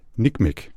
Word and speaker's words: NCMEC: Og NCMEC, e det udtales åbenbart, når man skal være hurtig, nikmek.